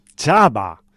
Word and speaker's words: Chhaava: Esta semana tenemos un estreno de una peli histórica, superproducción india, llamada... Chhava. Esto se escribe Chhaava y dirige el señor Laxman Utekar.